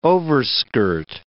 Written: overskirt

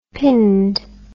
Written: pinned

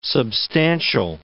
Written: substantial

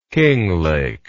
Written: Kinglake

